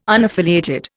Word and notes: unaffiliated: Indian

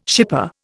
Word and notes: shipper: Indian

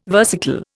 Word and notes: versicle: Indian

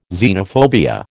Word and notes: xenophobia: American English

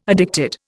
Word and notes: addicted: Indian

